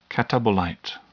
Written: catabolite

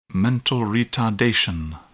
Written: mental retardation

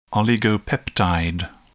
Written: oligopeptide

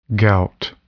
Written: gout

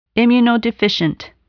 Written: immunodeficient

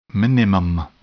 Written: minimum

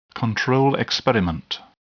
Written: control experiment

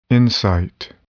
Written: insight

